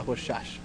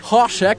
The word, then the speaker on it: Rorschach: Como é que fala Rorschach? – Rorschach. – Rorschach, caralho.